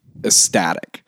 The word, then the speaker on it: ecstatic: You just didn't really pronounce the C of ecstatic.